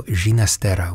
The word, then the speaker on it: Ginastera: The Argentine Dances, the opus 2 of the 21-year-old Alberto Ginastera with his Catalan father. That's how he pronounced it, at least at the beginning of his life.